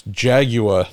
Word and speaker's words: Jaguar: Yes, and to which you replied, there's no W in Jaguar. And based on that, because I've heard that from friends from the UK forever about how we in America mispronounce it's Jaguar, not Jaguar.